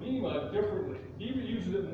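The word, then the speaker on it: bima (βῆμα): It is a Greek word, it's pronounced bima. Bima, and so it has a sense of judgment seat.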